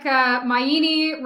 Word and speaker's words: Maini: It's like Maini rhymes with linguini.